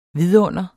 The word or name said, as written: vidunder